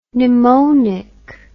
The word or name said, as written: Nimonic